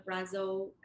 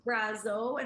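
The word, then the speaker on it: Brazeau: because I know your firm worked on the Brazo v.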